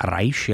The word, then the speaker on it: Reich: No a dále nás za chvíli čeká hudba minimalistického amerického autora, který se jmenuje Steve Reich, nebo Reich, jak to někdy Američané vyslovují.